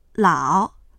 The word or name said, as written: lao3